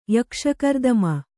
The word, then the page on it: ಯಕ್ಷಕರ್ದಮ: Kannada